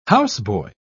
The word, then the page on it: houseboy: British English